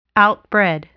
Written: outbred

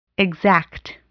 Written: exact

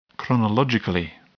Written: chronologically

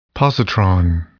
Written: positron

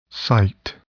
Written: cite

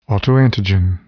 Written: autoantigen

